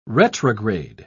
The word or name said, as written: retrograde